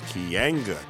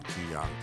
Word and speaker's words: Keeanga: I learned that I have a hard time pronouncing the hard A sound. It's Keeanga, Taylor, not Keeanga.